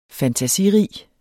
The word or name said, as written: fantasirig